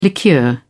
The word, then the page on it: liqueur: British English